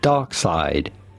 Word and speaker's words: Darkseid: It's simply Darkseid. – So it is Darkseid, not Darkseid. Darkseid. – All right. – D-A-R.